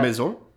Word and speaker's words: Maison: I mean, they even built the La Grande Maison. I don't know how to pronounce that. – Maison? – Maison.